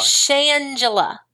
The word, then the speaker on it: Shangela: Shangela. – It's so, like Angela, only with an S-H.